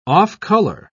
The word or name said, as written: off-color